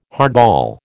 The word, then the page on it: hardball: American English